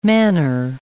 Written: manner

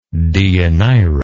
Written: deianira

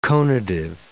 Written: conative

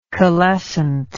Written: calescent

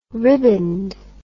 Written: ribboned

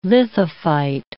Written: lithophyte

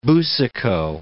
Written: Boucicault

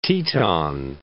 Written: Grand Teton

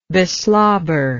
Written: beslobber

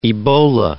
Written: Ebola

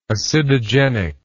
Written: acidogenic

